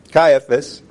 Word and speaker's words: Caiaphas: Then he's going to go through the trials with the religious people and Caiaphas. That's not how you pronounce it. Yeah, Caiaphas.